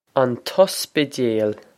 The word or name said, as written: an t-ospidéal